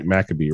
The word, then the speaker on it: Maccabee: Hopefully I'm saying that right, Maccabee, right? – That's right.